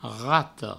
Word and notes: rata: rato

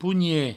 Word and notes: ponhet: pougnet; provençal

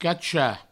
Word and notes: cachar: cacha

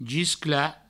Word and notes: gisclar: giscla

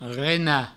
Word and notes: renar: rena

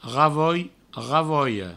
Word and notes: ravoi, ravoia: ravoi, ravoio

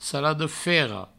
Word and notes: saladafera: saladofero

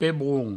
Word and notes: pebron: pebroun; provencal

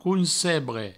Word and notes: concebre: councebre

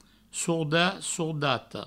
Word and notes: sordat, sordata: sourdat; sourdato

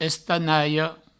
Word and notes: estanalhas: Provençal; estanaio

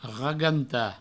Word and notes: ragantar: provençal; raganta

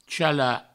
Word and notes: chalar: chala; provençal

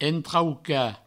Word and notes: entraucar: entrauca; provençal

